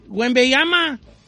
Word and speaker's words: Wembanyama: Don Cheto, a ver, dígame, ¿cómo se dice? – Wembanyama. – Wembanyama. – Dile Wembanyama, como le dice todo el mundo.